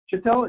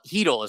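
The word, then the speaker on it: Chytil: I saw you guys just called up Chittell. Is that his last name? – Heedle is how you pronounce it.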